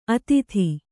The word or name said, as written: ಅತೀತ